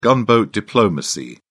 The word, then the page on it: gunboat diplomacy: British English